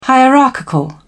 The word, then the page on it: hierarchical: British English